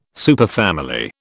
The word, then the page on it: superfamily: British English